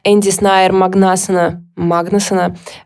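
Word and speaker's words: Andri Snær Magnason: Энди Снайер Магнасона, как в Исландии ставится правильное ударение.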